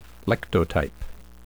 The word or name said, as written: lectotype